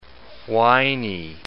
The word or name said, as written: whiney